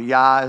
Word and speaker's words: Yah: Jah or Yah is how you pronounce it in the Hebrew. That name of God, Yah, it's a contraction of the name Yahweh.